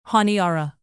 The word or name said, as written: honiara